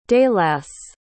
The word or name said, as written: daileass